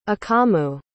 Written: akamu

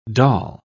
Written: dal

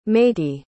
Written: matie

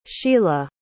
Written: shila

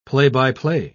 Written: play-by-play

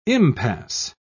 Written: impasse

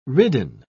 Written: ridden

